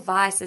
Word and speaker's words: Weisz: Rachel Weisz, and it's pronounced Weisz, people, we've Googled it, it's correct, has had a baby at 48.